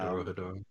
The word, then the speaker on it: Dorohedoro: I think it's Dorohedoro. – Yeah, Dorohedoro.